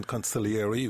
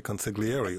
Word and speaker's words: consigliere: You pronounce the word correctly in the Italian, conciliary. We tend to say consigliere over here, but either or, tomato, tomato, it doesn't really matter.